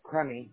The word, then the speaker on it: Crummy: Oh, Crummy. – Could you spell that? – C-R-U-M-M-Y. This is like it sounds. It's a crummy day.